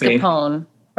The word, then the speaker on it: mascarpone: And then lime and Mars, I say it wrong, mascarpone. – I put the R in there too, mascarpone. That must be a Midwestern thing, but yeah, it's mascarpone, right?